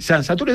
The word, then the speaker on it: San Saturio: Las fiestas de San Saturio, se dice así, San Saturio,